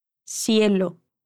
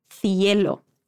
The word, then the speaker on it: cielo: Dice cielo, cielo. Bueno, es española, así que vamos a escuchar un poco la distinción, un fenómeno de pronunciación típico de España.